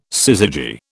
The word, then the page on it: syzygy: British English